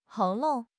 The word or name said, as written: 喉咙